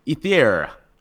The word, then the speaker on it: Ether: Ether.